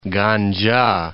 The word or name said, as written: Ganca